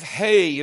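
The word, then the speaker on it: He: Number five, hey is how you pronounce the letters H-E in our English.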